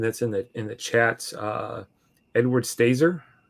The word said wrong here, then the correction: Stalzer: if I said that correctly. – Stalzer, I think. – Stalzer, yeah.